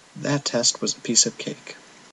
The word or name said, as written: A Piece of Cake